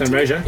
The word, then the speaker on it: Sunraysia: Sunraysia, yeah.